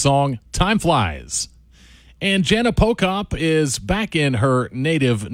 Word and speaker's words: Pochop: If you're finding out about her for the first time, that last name is P-O-C-H-O-P, pronounced Jana Pocop.